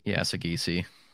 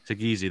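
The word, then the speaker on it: Saggese: Is it Sagesi? – Sagesi. – Sagesi, there we go.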